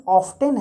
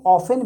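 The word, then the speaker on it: often: O F T E N का जो pronunciation ऑफ्टेन है शायद ये अमेरिकन हो मैं कंफर्म नहीं हूँ लेकिन होना चाहिए यही तो उसके दो प्रोनाउंसिएशन है उसको ऑफेन भी पढ़ा जाता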